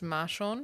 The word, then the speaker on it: Marshawn: I think it's Marshawn.